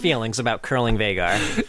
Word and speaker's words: Veigar: It's pronounced like vine. Veigar, Veigar joke.